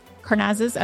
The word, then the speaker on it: Karnazes: So we hear more about cross country with running with the Buffaloes all the way up to those ultra marathons with Dean Karnazes. How do I pronounce his name, coach? – Karnazes.